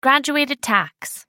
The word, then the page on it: graduated tax: American English